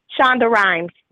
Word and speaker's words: Shonda Rhimes: Shonda Rhimes.